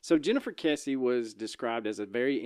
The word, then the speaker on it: Kesse: How did we decide to say this? Kesse or Kesse? – I think it's Kesse, but I said it wrong in the intro. – Okay, so we'll go with Kesse.